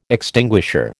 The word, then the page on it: extinguisher: American English